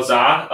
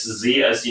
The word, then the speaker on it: Zha: It's just my name, Carl Za. That's Carl, start spelled with a C, C-A-R-L. And my last name, Z-H-A, that's Z as in zebra, H as in Henry, A as in apple.